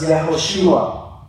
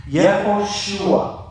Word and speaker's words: Yehoshua: In Hebrew, it's pronounced Yehoshua. Yehoshua.